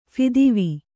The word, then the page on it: ಫಿದ್ವಿ: Kannada